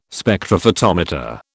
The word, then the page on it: Spectrophotometer: British English